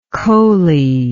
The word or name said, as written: coly